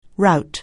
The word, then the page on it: rout: anglais